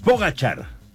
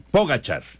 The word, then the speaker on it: Pogačar: Es Pogačar. Ya nos decía Memo García y ya lo estábamos checando también acá en diferentes páginas. Pogačar. La pronunciación correcta.